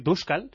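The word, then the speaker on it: Dočkal: Me gusta mucho Duskal. El que se escribe Dokal, pero le escuché a un narrador checo decir Duskal,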